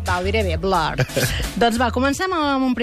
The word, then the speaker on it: Blur: I ja ho vam fer la setmana passada amb Oasis, i aquesta setmana ho farem amb els Blur o Blur. Perquè, val, clar, si ho volguéssim pronunciar bé, hauríem de dir Blur.